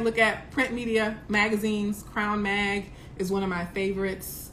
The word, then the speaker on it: CRWN: Crown Mag is spelled C-R-W-N, so no O, just C-R-W-N-M-A-G, Crown Mag.